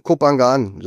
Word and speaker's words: Koh Phangan: Ja, auch liebe Grüße von mir, von Koh Phangan, so nennen wir das hier. Aber du kannst es aussprechen, wie du willst, ist auch egal. Jeder Tourist spricht es anders aus.